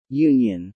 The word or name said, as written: Union